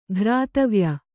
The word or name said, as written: ಘ್ರಾತವ್ಯ